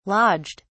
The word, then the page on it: lodged: British English